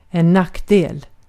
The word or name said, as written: nackdel